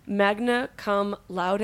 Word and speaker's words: magna cum laude: Mackenzie was born on Valentine's Day, destiny, raised in Connecticut, maybe also destiny, and graduated from the Maryland Institute College of Art, magna cum laude. Did I say it right? – You did. – Cool. – You nailed it.